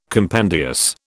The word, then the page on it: compendious: British English